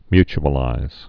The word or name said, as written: mutualize